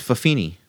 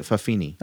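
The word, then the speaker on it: fa'afafine: It's fafini. It is. It's faafafine. Fafafine. – Yeah, they pr- they pronounce it fafini.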